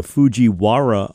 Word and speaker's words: Fujiwara: We come back talking to Barry Keim, our friend, LSU health climatologist, about the Fujiwara effect.